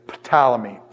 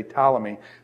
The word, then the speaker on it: Ptolemy: I've got a friend, Dave Mathewson, who's a phenomenal New Testament scholar, and Dave says Ptolemy, Ptolemy. He pronounces the P. I do it more like pneumonia, where the P goes silent, so I'd say Ptolemy.